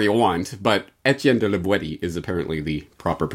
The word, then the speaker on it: La Boétie: Properly pronounced not, as might be thought, La Boétie, but rather La Boétie, with the hard T, as it was pronounced in the Périgord dialect of the region in which La Boétie lived.